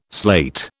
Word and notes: sleight: British English

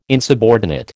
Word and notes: insubordinate: American English

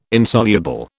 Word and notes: Insoluble: British English

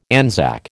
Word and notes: Anzac: American English

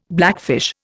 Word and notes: blackfish: Indian

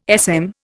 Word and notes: SM: Indian

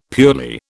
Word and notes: purely: British English

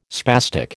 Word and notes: spastic: American English

